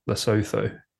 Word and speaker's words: Lesotho: Is it pronounced Lesotho? Is that spelled Lesotho? Lesotho. Is that right? – Yes.